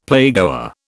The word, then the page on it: playgoer: British English